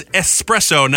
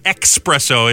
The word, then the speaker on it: espresso: And it's espresso, not expresso. It's not like express the clothing store. It's espresso.